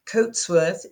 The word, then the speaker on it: Coatsworth: Coatsworth is C O A T for Tom, S for Sam, W O R T H.